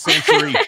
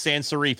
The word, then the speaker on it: sans serif: It's sans serif. That's the font. It's sans serif.